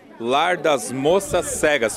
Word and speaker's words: Lar das Moças Cegas: Pessoal, eu estou aqui com o Gabriel e com o Rafael, que eles são da banda marcial Lar das Moças Cegas. Falei certo, Rafael? – Isso, correto.